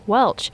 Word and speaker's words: Welch: And her last name was Welch, which is my last name, W-E-L-C-H.